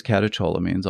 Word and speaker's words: catecholamines: I can clear out all these catecholamines, all the painkillers. Mm. Catecholamines. Chol? Coal. Uh, Canadian.